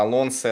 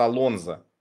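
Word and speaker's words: Alonso: Вот мы всегда думали, что э, типа Алонсо это Алонсо, а он оказался Алонзо.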